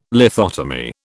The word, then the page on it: lithotomy: British English